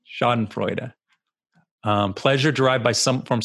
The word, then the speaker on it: Schadenfreude: And but what I didn't, it's what, what's the word, is it Freudenschoiden or oh man, now you're making me mispronounce it, but it's the German word for taking joy in other people's misery. – Hold on. – Schadenfreude or something.